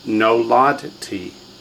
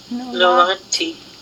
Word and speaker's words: Noladti: It's Noladti. – Noladti. – Uh-huh. No, lod, t, three full syllables. No, lod, t. So instead of ni, it begins no.